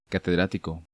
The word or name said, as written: Catedratico